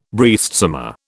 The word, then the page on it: breastsummer: British English